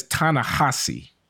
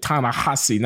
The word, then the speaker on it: Ta-Nehisi: David, I think he says Ta-Nehisi. I think he pronounces it Ta-Nehisi, notwithstanding the H-I-S-I. – All right, Ta-Nehisi.